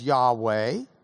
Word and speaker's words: Yahweh: We often hear it pronounced as Yahweh, but it's translated in our Bibles as Lord using all capital letters.